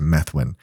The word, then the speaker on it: Methuen: Subsequent legislation introduced by the state senator Diana DiZoglio, a Democrat from Menthuen, Methuen, I think it's pronounced Methuen, was tacked onto a budget bill and approved.